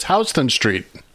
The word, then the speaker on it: Houston Street: It's Houston Street. We have a house. – Hey. That's Houston Street. That's not Houston.